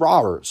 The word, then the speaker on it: Robert: Tim Anderson, Yoan Moncada, Jose Abreu, Eloy Jimenez, Yasmani Grandal, Luis Robert, I don't know why I pronounce it Robert. I feel like when he first came in the league, they said Robert. Now it's Robert, so let's call him Luis Robert.